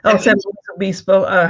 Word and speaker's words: San Luis Obispo: San Luis Obispo.